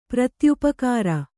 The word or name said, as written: ಪ್ರತ್ಯುಪಕಾರ